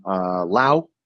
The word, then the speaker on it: Laos: Just returned back to Thailand from Laos. And for all y'all who don't know, you don't pronounce that S on the end.